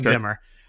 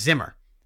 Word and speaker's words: Ziemmer: But Cohen Zimmer, and I want to spell his name for you, pronounced Cohen Zimmer. It is K-O-E-H-N, pronounced Cohen. – Okay. – Z-I-E-M-M-E-R, pronounced Zimmer.